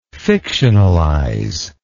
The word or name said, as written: fictionalizer